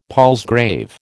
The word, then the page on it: palsgrave: American English